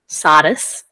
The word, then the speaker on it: Sardis: Sardis, yeah. – Is that how the Greeks would say it? Sardis? – No, it's a bit more of an Italian pronunciation rather than a Greek.